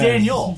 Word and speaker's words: Daniel: Do you not pronounce the S? – No, there's no S in his name. It's just Daniel.